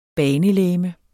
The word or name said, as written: banelegeme